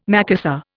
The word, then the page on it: Macassar: Indian